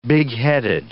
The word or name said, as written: bigheaded